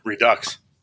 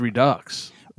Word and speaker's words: Redux: Is that Redux or Redo? How do we say that word? – Redux. – I think it's Redux.